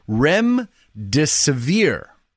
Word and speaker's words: Remdesivir: It's pronounced Remdesivir, which I guess is pretty good.